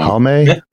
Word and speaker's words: Halme: H-A-L-M-E. – Halm. – Halme?